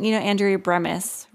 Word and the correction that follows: Bemis: Is that her name? Did I say it right? – Bemis, I think. – Bemis.